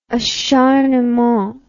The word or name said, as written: acharnement